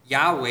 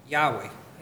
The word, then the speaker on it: Yahweh: The tetragrammaton, the four consonants that make up God's name, are assumed or speculated to be pronounced Yahweh.